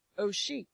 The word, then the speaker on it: osheq: The Hebrew word for oppression, pronounced oshek, O-S-H-E-Q, meaning oppression, extortion, to obtain from a person by force, intimidation, or undue illegal power, cruelty.